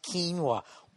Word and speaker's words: quinoa: I guess quinoa, even though it's spelt O-A, is not the way to say it. Some fucking asshole on Twitter's giving me shit. – You're driving me crazy. It's pronounced quinoa.